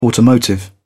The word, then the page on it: automotive: British English